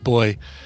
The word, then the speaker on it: buoy: We have a rule around poke at the silly way that she pronounces buoy. – Yeah. Buoy. – By the way, apparently that is a UK thing. So Zoe confirmed that it's pronounced boy in the UK.